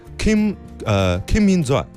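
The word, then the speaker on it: Kim Min Zai (김민재): 对，其实这个就是翻译的问题，因为它是韩语读起来就是 Kim Min Zai 啊，Kim Min Zai，就这种感觉。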